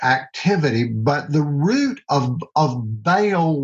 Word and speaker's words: Baal: But the name Baal, some people pronounce it Baal. It's B-A-A-L. Whether you pronounce it Baal or Baal, it is the same thing.